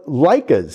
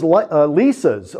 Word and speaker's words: Leica: I said, "What are those Leicas over there?" And he goes, no, it's Leica. You're saying it wrong."